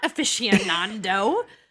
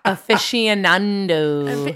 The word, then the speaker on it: aficionado: I mean, this was a couple months ago, maybe 6 months ago, that Dad was like, it's not aficionando. – No. – And it blew my mind. I couldn't believe that I was saying it wrong for 35 years. – I hope everyone's saying it right now. Aficionado.